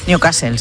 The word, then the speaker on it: Newcastle: Newcastle, sí.